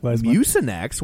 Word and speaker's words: Mucinex: But I'm like Mucinex. Why would you make that a soft C? It's a hard C, like my ex-wife.